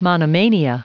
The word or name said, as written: monomania